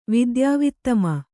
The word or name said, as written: ವಿದ್ಯಾವಿತ್ತಮ